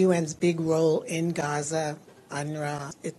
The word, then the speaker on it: UNRWA: The W is silent in yes. – UNRWA.